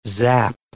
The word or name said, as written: zap